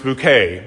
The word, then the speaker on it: Bucket: And her last name is Bucket, but she insists upon it being pronounced Bouquet.